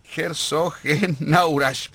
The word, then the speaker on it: Herzogenaurach: Sí, hay una joda que le hacíamos porque la primera vez que lo pronunció dijo Herzogenrausch. – Ya está la selección nacional en la ciudad de Herzogenrausch. Herzogenaurach, perdón.